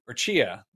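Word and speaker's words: Tchia: Chia. I always want to say the T. The T is silent.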